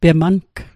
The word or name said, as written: pemang